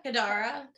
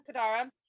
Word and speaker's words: Kadara: But he reigned in, how do you say the his rook's name? Carda? – Kadara. – Kadara.